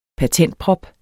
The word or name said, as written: patentprop